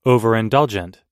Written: overindulgent

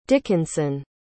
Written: dickinson

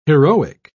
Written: heroic

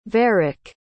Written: varick

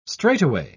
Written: straightaway

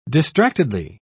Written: distractedly

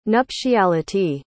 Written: nuptiality

